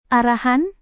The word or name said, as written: arahan